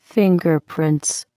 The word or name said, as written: fingerprints